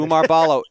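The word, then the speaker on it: Oumar Ballo: And then also, in keeping in line what we mentioned with how they've been able to produce bigs reliably, not just him, I don't know how to for sure to pronounce this young man's first name, but Umar Ballo. – That's right. – Okay. – I looked it up before we started.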